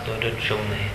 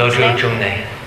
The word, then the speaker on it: Lodru Jungne: Se lo conoció como Geshe Trolungpa y su nombre es Lodru Jungne. – Lodru Jungne. – Ahí está. Bien. – Yeah, se escribe L-O-D-R-U, Lodru, y separado J-U-N-G-N-E, Jungne. – That's the monk. Lodru Jungne.